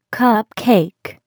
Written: cupcake

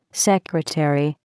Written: secretary

